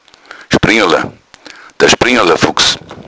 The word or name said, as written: springala